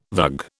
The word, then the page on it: vug: British English